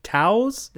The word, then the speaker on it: Tao: It's the five Taos. – The Tao of Javelina. – Taos of Javelina. – Yeah, it's pronounced with a D. You would never know that.